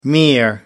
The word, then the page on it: mere: American English